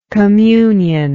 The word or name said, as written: communionally